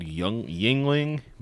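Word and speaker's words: Yuengling: And then one called Yingling, but it's spelled like Youngling, like it's weird, like it's a weird name.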